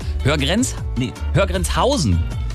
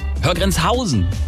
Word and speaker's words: Höhr-Grenzhausen: Häufig liegt die Betonung auf Höhr. Und der Bindestrich wird deutlich gemacht. Grüße an Wirbi. Hey, wir nennen keine Namen hier in dieser Sendung. Richtig ist die Betonung auf der dritten Silbe aber. Höhr-Grenzhausen. Höhr-Grenzhausen. So muss es wohl richtig ausgesprochen werden.